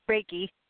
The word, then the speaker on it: Reiki: Reiki.